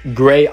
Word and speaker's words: grxay: Das wird Gray ausgesprochen und das x hat einfach nur einen ästhetischen Grund, weil es halt einfach geiler aussieht.